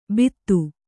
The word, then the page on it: ಬಿದು: bidu